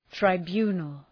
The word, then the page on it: tribunal: traı’bju:nəl